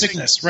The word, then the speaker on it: Cygnus: And when the Palomino docked with the Cirrus, I say that right? – Cygnus. – Cygnus, right. Sorry.